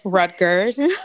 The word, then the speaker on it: Rutgers: Rutgers.